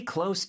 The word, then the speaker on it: close: Our expression was, stay close to me. – Stay close to me. 자, 여기서 close의 발음이 굉장히 중요하다고 했죠. close라고 하면은 여기서 S 발음을 해주셔야지 Z 발음으로 하면 닫다라는 뜻의 동사가 된다고 했어요.